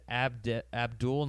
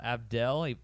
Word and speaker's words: Abdel: Apparently his name is Abdul, not Abdel, the way it's spelled.